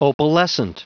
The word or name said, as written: opalescent